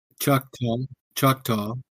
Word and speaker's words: Choctaw: It's Choctaw.